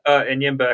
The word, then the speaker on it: Enyimba: Enyimba Economic City.